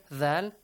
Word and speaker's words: ذ: Then you have thal. – Th, thal, tip of the tongue, good.